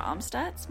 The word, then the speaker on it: Amstutz: You can find me on all social media platforms at Sandra Amstutz. My last name is spelled AMSTUTZ.